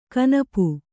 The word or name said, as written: ಕನಪು